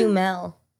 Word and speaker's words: Duhamel: It's Dumel. – It's Dumel? – Yeah. – Yeah, not Duhamel. it's spelled Duhamel.